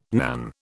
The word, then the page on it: nan: British English